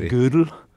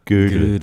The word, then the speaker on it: Gödel: I hear it often pronounced, well, I don't speak German, but mathematicians often say it like the woman's piece of clothing, you know, from the old days. But something like Gödel.